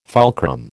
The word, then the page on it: fulcrum: American English